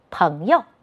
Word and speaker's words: 朋友: 注意，这里要读成轻声，朋友。